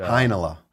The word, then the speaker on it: Heinola: So, Heinola. – Heinola. – Like vanilla and Heinz ketchup, but you kind of meld them together.